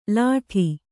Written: ಲಾಠಿ

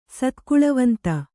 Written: ಸತ್ಕುಳವಂತ

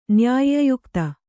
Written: ನ್ಯಾಯ್ಯಯುಕ್ತ

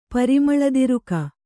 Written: ಪರಿಮಳದಿರುಕ

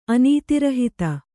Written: ಅನೀತಿರಹಿತ

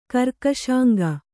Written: ಕರ್ಕಶಾಂಗ

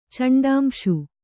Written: ಚಂಡಾಂಶು

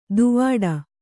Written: ದೂವ್ವಾಡ

